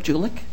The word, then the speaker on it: Juelich: Barb Julich. – How do you spell that last name? – J-U-E-L-I-C-H.